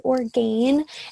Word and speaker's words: Orgain: I've been using Orgain protein. It's spelled like organ, but with an I after the A, Orgain.